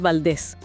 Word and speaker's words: Valdés: Matías Valdés, con acento en la E, sí.